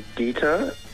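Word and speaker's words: geta: Shoes, the wooden soles, I believe it's pronounced Geeta. It's G-E-T-A.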